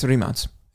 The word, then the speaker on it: Rimac: It's Rimac. That's how they say it. That's how they say it in Croatia. – So in America, when I say Rimac, mm-hmm.